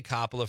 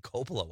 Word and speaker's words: Coppola: Thank you, Catherine, for I'm going to say Coppola from now on. I know that's the correct pronunciation, but for some reason I say Coppola.